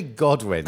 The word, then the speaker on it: Godwin: Did I say that right? – It's actually Godwin.